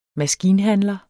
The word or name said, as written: maskinhandler